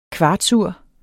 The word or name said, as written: kvartsur